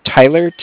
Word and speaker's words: Tyler: My name is Tyler, T-Y-L-E-R, uh-huh. – And I'm calling with Cardiff. It's spelled C-A-R-D-I-F-F, as in Frank Frank.